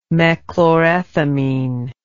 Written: mechlorethamine

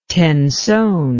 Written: tenson

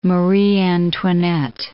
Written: Marie Antoinette

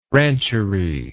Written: rancherie

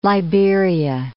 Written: Liberia

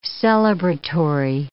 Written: celebratory

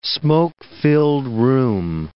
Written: smoke-filled room

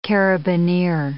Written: carabinier

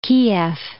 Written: Kiev

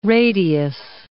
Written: radius

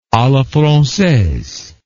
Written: a la francaise

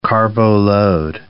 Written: carbo-load

